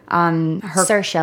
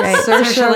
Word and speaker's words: Saoirse: Is that how you say your name? Mm-hmm. – Saoirse, like inertia. I remember just from SNL. – Yeah, that's right. – Saoirse, like inertia.